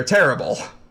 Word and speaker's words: Terrible: Well, being that Unibrow, they're located in Quebec, so it would be the Terrible. – The Terrible. – Yes. – Okay, but spelled terrible.